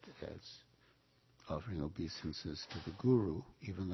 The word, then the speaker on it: guru: And in one talk Srila Prabhupada gave fairly early in the movement, he commented that the devotees were mispronouncing the word guru in Bande Guru Sri Charanaravinda and saying something like garo, which means cow.